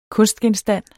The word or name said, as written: kunstgenstand